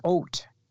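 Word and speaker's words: oat (אות): But in the English language, it is O-T-H, which is oat.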